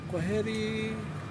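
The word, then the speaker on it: Kwaheri: How do you pronounce Quaheri? In Quaheri? Quaheri. K. Yes. W. Yeah. A. Yeah. H. Yeah. A. E. Yeah. – R. – I. Quaheri. – Quaheri. And what does it mean?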